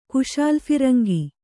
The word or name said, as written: ಕುಶಾಲುಫಿರಂಗಿ